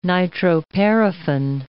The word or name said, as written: nitroparaffin